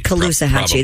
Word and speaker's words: Caloosahatchee: I don't know how to say this river's name. – It's Kalahusas Samahibo. – Kalasuhachi River. – Oh, that's beautiful. – The Kalasuhachi River? – No, your pronunciation. I think you nailed it. – Oh. Authorities recognized... – Caloosahatchee, that's it. You're exactly right.